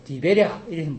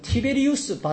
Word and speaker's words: 티베리우스: 근데 디베랴가 아니라 원래 이름은 티베리우스 바다 이래요.